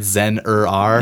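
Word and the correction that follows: Zur-En-Arrh: Yeah. – am I saying that right? – I think it's Zur-En-Arr. – Zur-En-Arr.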